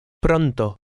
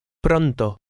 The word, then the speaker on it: Pronto: In number 4, be careful of the R sound, which is slightly trilled. Read word 4. – Pronto. Pronto.